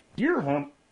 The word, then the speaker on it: dirham: An Emirati man was being sued for 45 million dirhams. For not having sex with his wife. – Dirham? – Dirham.